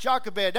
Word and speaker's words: Jochebed: Some people pronounce it in different ways, but I've heard it pronounced Jochebed.